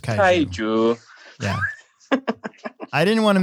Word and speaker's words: Kaiju: K-A-I-J-U. I'm pretty sure it's Kaiju.